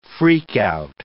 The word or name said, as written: freak-out